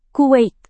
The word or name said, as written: Kuwait